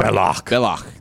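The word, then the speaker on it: Belloq: You're talking about Belloc? – Belloc. There you go. – They call him Belloche.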